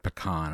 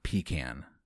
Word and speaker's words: pecan: I'm glad you went with the correct pronunciation of pecan and not pecan.